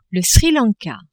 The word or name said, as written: Sri Lanka